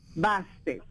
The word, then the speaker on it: Bastet: Basket. – Bastet. – ¿Cómo se escribe? – B-A-Bastet. – Bastet. – Bastet. B-A-S-T-E-T, Bastet.